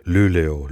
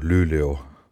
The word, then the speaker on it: Luleå: Luleå, Luleå, mit Betonung auf das Ü.